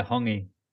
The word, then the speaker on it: hongi: Is it hungi or hungi or hungi? – Yeah, hungi. You're close.